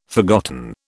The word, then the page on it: forgotten: British English